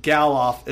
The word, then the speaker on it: Gelof: And for the folks at home, Gelof is spelled gel off. A gel of. Fuck. Screw that up. – You mean pronounced? You said spelled. – Whatever. Gel of.